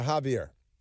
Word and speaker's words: Xavier: Javier, but he spells it Xavier with an X, but he pronounces it a Javier, which is illiterate, I think, because, you know, it's with an X.